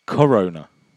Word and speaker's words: koruna: Is called korona. Czech Corona. – Like the 1970s fizzy drink? – No, this is spelled, I think, Czech. – It's K. – K-O-R-U-N-A, Corona. – Corona. It's with a K, yeah.